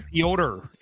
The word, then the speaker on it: Piotr: How do you say his name? Peter Piotr. It's Piotr, right? – Kochetkov made a dive.